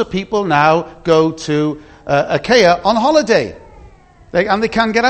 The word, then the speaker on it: Achaia: At the bottom, you've got Achaia. That's how you pronounce it. See the little reminder at the bottom? Achaia.